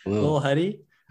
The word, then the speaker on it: Lil Huddy: Lil, like L-I-L. Lil Huddy.